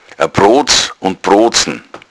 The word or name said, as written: proodzn